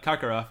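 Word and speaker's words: Karkaroff: I said that right? – Yep, Kakarov, yep. – I remembered it because, like, Dragon Ball Z.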